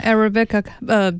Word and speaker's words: Arabica: Arabica? – Yeah, Arabica beans.